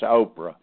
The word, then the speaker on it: Ophrah: That's Oprah, just like Oprah Winfrey.